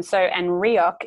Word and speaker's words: Reoch: And Reoch is spelled R-E-O-C-H.